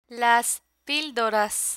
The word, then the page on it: las pildoras: Spanish